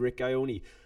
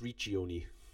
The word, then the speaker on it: Riccioni: I hear people pronounce it Rick Ioni, but my rudimentary Italian would suggest to me that's Riccioni.